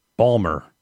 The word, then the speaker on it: Baltimore: Baltimore. Balmer. Like Steve Ballmer. That's how you pronounce it, right? – Yeah, it's named after him.